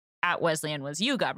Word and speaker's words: Wesleyan: Wesleyan.